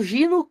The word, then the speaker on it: Geno: Não, o, se o Gino que se escreve Geno ganhar isso aqui, é, é, é eu, eu, entendo, tá?